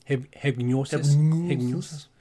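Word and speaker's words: Hipgnosis: I don't know if that's a soft G. Am I just going... – Hypgnosis. Right.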